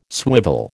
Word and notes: swivel: American English

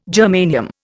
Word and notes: germanium: Indian

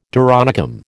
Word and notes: doronicum: American English

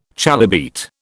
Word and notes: chalybeate: British English

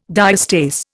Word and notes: diastase: Indian

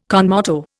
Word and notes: con moto: Indian

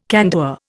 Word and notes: candour: Indian

